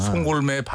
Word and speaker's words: 송골매: 근데 왜냐면 옛날에는 송골문만 해도 나이든 사람하고 어린 사람하고 전 세대적이었단 말이에요. 옛날 대중가요는. – 송골매 발음을 좀 똑바로 해주세요.